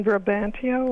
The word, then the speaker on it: Brabantio: And Brabantio, or have you said, is Desdemona's father? – That is how you say it in Othello.